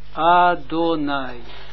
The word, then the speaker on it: Adonai: Adonai.